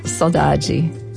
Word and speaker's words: saudade: And I have the Brazilian pronunciation, saudade.